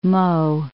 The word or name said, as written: Meaux